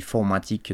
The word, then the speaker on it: M4tic: Alors ça s'écrit M4TIC, mais apparemment il dit formatique dans, le, dans son petit gaming de beatmaker, donc c'est comme ça que ça se prononce.